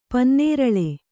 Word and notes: ಪನ್ನೀರೆಲೆ: Kannada

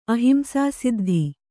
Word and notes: ಅಹಿಂಸಾಸಿದ್ಧಿ: ahimsāsiddhi

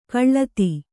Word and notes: ಖಳತಿ: khaḷati

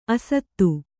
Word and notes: ಅಸಾಧು: kannada

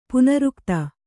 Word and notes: ಪುನರುಕ್ತ: punarukta

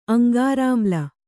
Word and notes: ಅಂಗಾರಾಮ್ಲ: aŋgārāmla

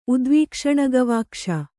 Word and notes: ಉದ್ವೀಕ್ಷಣಗವಾಕ್ಷ: udvīkṣaṇagavākṣa

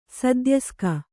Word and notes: ಸದ್ಯಸ್ಕ: sadyaska

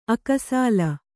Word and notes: ಅಕಸಾಲ: akasāla

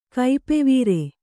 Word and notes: ಕೈಪೆವೀರೆ: kaipevīre

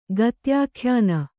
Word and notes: ಗತ್ಯಾಖ್ಯಾನ: gatyākhyāna

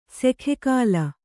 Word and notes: ಸೆಖೆಕಾಲ: sekhe kāla

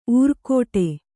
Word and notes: ಊರ್ಕೋಟೆ: ūrkōṭe

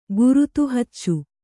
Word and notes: ಗುರುತುಹಚ್ಚು: Kannada

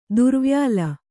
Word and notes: ತಿರುವೊಯ್ಲು: tiruvoylu

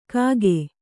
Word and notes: ಕಾಗೆ: Kannada

